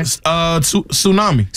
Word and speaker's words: tsunami: This has a silent letter in it. – Okay. – Tsunami. – Tsunami. – Tsunami. Yeah. – Tsunami. Sure does. – Now, some people might say tsunami. – Tsunami. – Tsunami. Somebody might say that. No. They'd be wrong.